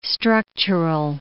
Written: structural

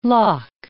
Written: lakh

